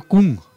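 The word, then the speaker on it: !Kung: Se escribe Kung. – Claro, Kung, con una interrogación.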